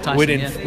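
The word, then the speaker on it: Thijssen: Tyson, within 3 months, had bought a snooker cue.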